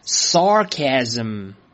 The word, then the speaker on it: sarcasm: 来，跟我一起念，sarcasm， sarcasm。注意啊，第一个音节是 sar，要去卷舌。如果是美式发音的话，要去卷舌，sarcasm。